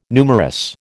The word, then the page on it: numerous: American English